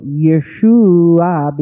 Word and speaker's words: Yeshua: So Yeshua became Yeshu, Yesu, and then the ye became je and became Jesu. So it's basically it's the Greek way of pronouncing a Hebrew word. – Okay. – When you say Jesus, you're still actually saying Yeshua, but you're just pronouncing it in Greek pronunciation.